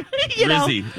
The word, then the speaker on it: rizz: Rizzy. – Yeah, no one says Rizzy. – But it's Riz. – It's short for charisma.